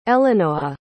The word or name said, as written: elinoah